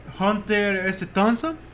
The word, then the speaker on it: Hunter S. Thompson: Ok, pues sigues tú, Mira, este, te voy a leer la de Hunter S. Thompson.